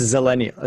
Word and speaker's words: zillennial: It's pronounced zillennial. It's Oh.